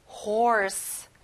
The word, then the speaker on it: horse: 말, horse. 여기는 OR 소리가 나네요. Horse. Good job.